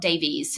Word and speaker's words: Davies: So my Instagram handle is Anna Lois Davis, and my surname is spelt Davies, so it's D-A-V-I-E-S rather than I-S.